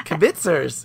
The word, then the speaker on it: kibitzers: So I will book talk the next one, which I don't know how to pronounce that first word. Do you? – Kibitzers. – Okay, kibitzers. I just didn't want to say it wrong. So kibitzers.